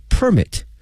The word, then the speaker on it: permit: 工作许可证的这个发放，以及呢签证服务呢同时政府呢在工作的力度上呢也有所提升，让外籍人士呢感觉到整个的满意度呢在不断的提升。好，那么看这句话的翻译， In recent years implementation of convenient working permit 一个便利的工作许可 work permit，有时候也可以叫做 working permit。同学们同时注意一下呢，这个许可这个词呢 P-E-R-M-I-T 呢它的发音不是 permit，